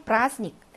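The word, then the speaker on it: 브라스닉 (праздник): 4번, 브라스닉. 자, 브라스닉 할 때는 대발음이 묵음이라는 것이 묵음 문제에서 많이 듭니다.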